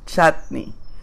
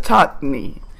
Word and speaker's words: chutney: El chutney de mango tiene un nombre original en India, que es chutney. Chutney. Lo de chutney es que así lo pronuncian los, ingleses, chutney.